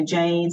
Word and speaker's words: Jayde: So LinkedIn is probably the best place, and it's just simply my name, so Jayde, spelled with a Y, so J-A-Y-D-E, and then Phillips with two L's, and happy to connect with people and network as well.